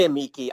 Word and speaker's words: amici: I've heard it both ways when I took Latin. – Was, I say amici. I say it like a hard key.